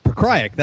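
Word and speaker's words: Pokrajac: First fight was Igor Polkrajak. Versus Todd Brown. Goes from complicated name to Todd Brown. – Pokrajak, that's how you say it.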